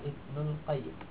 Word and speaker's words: Ibnul Qayyim: al-jawziyahnya dihilangkan, maka Ibnul Qayyim. Itu cara baca yang benar.